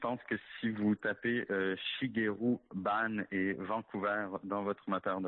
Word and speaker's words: Shigeru Ban: Et voilà, donc le nom de cet architecte s'appelle à peu près comme il se prononce, Shigeru Ban, Shigeru, espace Ban.